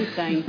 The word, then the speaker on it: Weinstein: Essas pessoas todas passaram, eu não sei pronunciar o nome do Harvey Weinstein. – Weinstein, é.